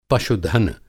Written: पशुधन

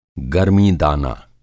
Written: गरमी दाना

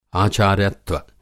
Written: आचार्यत्व

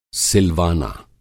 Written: सिलवाना

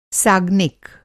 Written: साग्निक

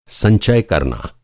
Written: संचय

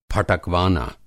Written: फटकवाना

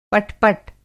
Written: पट-पट

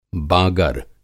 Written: बागर

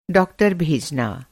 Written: भेजना

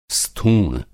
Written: स्थूण

